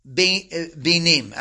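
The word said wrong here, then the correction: beinayim (בינים): I'm sorry, I'm not pronouncing it correctly. It's beinayim. Thank you.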